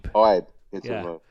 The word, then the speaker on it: Ibe: Yeah, one syllable, Jordan it's Ibe.